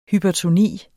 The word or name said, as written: hypertoni